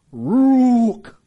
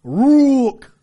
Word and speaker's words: ruach: It's pronounced Ruach. Ruach.